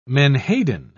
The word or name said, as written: menhaden